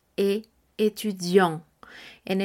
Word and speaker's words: étudiante: En el caso de ser varón, masculino, el término, será tu e étudiant. En el caso que sea mujer, femenino, estaremos diciendo tu e étudiante. Estaré diciendo la T del final. ¿Por qué? Porque estudiante se escribe de la siguiente manera. E-T-U-D-I-A-N-T en el caso del masculino y en el caso del femenino, E, T, U, D, I, A, N, T, E.